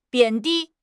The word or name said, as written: 贬低